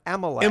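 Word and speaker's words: Amélie: Emily. – Loved. Emily. Well, it's Amelie. – Emily. – And no, it's not Emily with Emily. E. It's Amelie.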